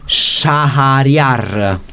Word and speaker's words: Shahriar: Stiamo nell'harem precisamente del sovrano Shahriar.